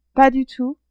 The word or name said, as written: pas du tout